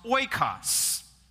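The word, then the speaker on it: oikos (οἶκος): And it's a Greek word that's pronounced oikos.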